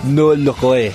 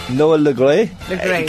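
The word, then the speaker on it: Noël Le Graët: I was like, before the show, how do you pronounce that? He's like, Noah Legault. And I was like, ah, here. – Say it again. – Noah Legault.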